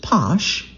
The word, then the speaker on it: Pox: One of the first commercial bottlings of Pox, I'm seeing it written P-O-X, but they pronounce it Posh, available in the United States.